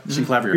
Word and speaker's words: Synclavier: Synclavier.